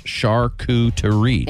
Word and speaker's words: charcuterie: And they say it's pronounced charcuterie. – Yeah, just like charcuterie.